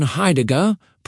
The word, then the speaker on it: Heidegger: Martin Heidegger, pronounced Heidegger, was a philosopher who asked a question that seems simple but is actually enormous.